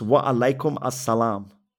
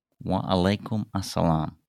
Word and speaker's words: Wa alaikum assalam: It's Wa Alaikum Assalam. – Wa Alaikum Assalam.